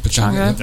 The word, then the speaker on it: Pechanga: Is that how you say it? – Pechanga.